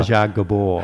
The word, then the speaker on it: Zsa Zsa Gabor: Zsa Zsa Gabor.